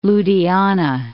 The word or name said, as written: Ludhiana